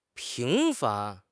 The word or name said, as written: 平凡